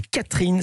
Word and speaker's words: Katrijn: Et là, vous avez publié votre premier roman, ça s'appelle Catherine. Ça s'écrit K-A-T-R-I-J-N. Hasard, je ne m'attendais pas à penser à ça. Elle est hollandaise. – C'est ça. C'est le IJ, c'est du néerlandais, oui.